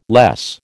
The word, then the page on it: less: American English